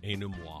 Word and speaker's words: Ennui: Ennui.